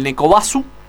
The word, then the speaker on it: Nekobasu: El Nekobasu. Neko es este, sí, es este. Sí, Basu es de la pronunciación de Bus también. Sí, sí, la derivación. Basu.